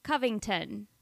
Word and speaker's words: Covington: He says, "Chuck, It is pronounced with the first O making a U sound. So Covington, Kentucky.